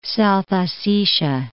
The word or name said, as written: South Ossetia